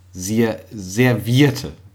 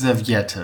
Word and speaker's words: Serviette: Es heißt ja servierte. – Ja. – Also im Grunde Serviette. – Eigentlich heißt es Serviette. – Genau. Serviette. Und ich habe es immer ausgesprochen und hätte es auch wahrscheinlich instinktiv so geschrieben. Servierte. – Ja. – Ich hätte immer noch ein R reingehauen und das zweite T weggelassen. – Der Servierte. – Servierte, genau. Genau. Ja. Wie das servierte Gericht nur als Substantiv.